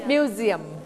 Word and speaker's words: museum: มี5 ตัว a e i o u อันนี้ s อยู่ระหว่างสระ 2 ตัวเสียงตัว s เวลาเราออกเสียงให้เปลี่ยนเสียงเป็นเสียงตัวเสียงตัว z ทันทีดังนั้นเราไม่ออกเสียงว่ามิวเซียมเราเสียงว่ามิวเซียมมิวเซียม